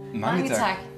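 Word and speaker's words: mange tak: Mangatak. – Mangatak? Yes. – Si. – Mangatak. – That's right. – And that's a good pronunciation, actually.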